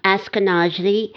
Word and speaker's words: Ashkenazi: So I've heard the word, you'll have to correct me if I don't say it right, but I believe it's Ashkenazi. – Ashkenazi.